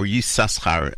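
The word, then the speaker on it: Issachar: You may be familiar with the name Na'ama Yisachar, or Yisachar, actually, is the way they pronounce it.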